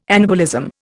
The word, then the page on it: anabolism: Indian